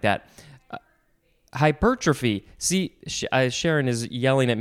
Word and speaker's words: hypertrophy: I thought that the enunciation or the emphasis was might be on a different syllable, but I couldn't figure it out in my brain. Hypertrophy.